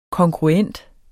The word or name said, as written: kongruent